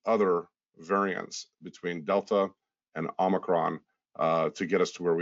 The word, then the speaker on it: Omicron: So again, a lot of mispronunciation of Omicron and stuff like that, but the proper pronunciation of it is Omicron. So we had seen a few other variants between Delta and what we've got right now.